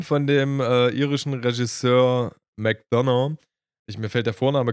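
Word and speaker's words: McDonagh: sein McDonough, der wird so eigentlich McDonough äh, wird der äh, geschrieben D O N A G H, glaube ich, also wird McDonough ausgesprochen.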